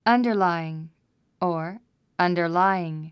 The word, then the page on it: underlying: ʌ̀ndə r láiiŋ; アンダァライィン